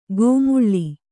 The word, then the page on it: ಗೋಮುಳ್ಳಿ: gō muḷḷi